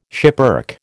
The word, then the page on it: schipperke: American English